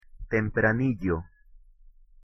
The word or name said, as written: tempranillo